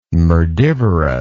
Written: merdivorous

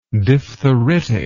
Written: diphtheritically

